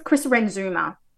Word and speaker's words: Chris Renzema: I think it's Chris Renzema is how you say his name, according to a story that he posted on Instagram recently.